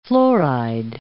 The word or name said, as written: fluoride